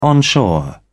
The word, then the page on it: onshore: British English